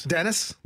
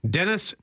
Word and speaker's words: Denis: Canada's, is it Denis or Dennis? – Dennis. – Dennis Shapovalov. – Done perfectly.